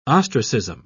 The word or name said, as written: ostracism